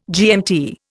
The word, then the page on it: GMT: Indian